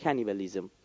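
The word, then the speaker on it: cannibalism: اینجوری اگه بخوام تلفظ درست لاتینش میگه کنیبالیزم، آدمخواری از این گرفته شد.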